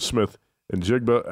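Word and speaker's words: Smith-Njigba: Jackson Smith and Jigba at uh, wide receiver. I don't think I pronounced that. Correctly. No, that's right.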